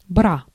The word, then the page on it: candélabre: /kɑ̃.de.labʁ/; [kɑ̃.de.labʁ]